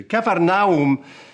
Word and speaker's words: Capernaum: Capernaum, the way I pronounce it, all people say all kinds of things, Capernaum, and take my advice, say what you like. It's simply Kafar is town and Nahum, as in the prophet, is a name.